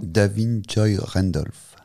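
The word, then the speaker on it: Da'Vine Joy Randolph: Davin Joy Randolph.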